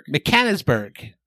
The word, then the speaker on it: Mechanicsburg: In 1983, they are from, let me see if I can pronounce this, Mecklenburg, Pennsylvania. – Pennsylvania. – Mechanicsburg.